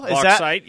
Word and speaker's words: Bauxite: Bauxite, you are correct. – I Googled how to pronounce Bauxite, Arkansas today, and I'm glad I found the correct pronunciation.